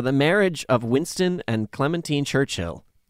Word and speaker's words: Clementine: We are going to talk about Winston and Clementine Churchill. And I'll tell you right now, if you've been saying Clementine like I was, you're saying it wrong. It was Clementine.